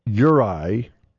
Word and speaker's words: Juraj: Juraj Slavkovsky puts on the moves, opens up the space, and it's 5-2 Montreal. – Well, they did it. That's Y-U-R-A-J, Juraj.